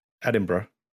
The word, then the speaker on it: Edinburgh: See, I Americanized that pronunciation and everything. Yeah, I flew into Edinburgh. Yes. – Oh, well, what's the correct pronunciation? – Edinburgh.